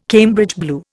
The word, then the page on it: Cambridge blue: Indian